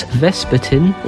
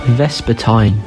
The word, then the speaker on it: vespertine: This week's word is vespertine, also pronounced vespertine. It is spelled V-E-S-P-E-R-T-I-N-E.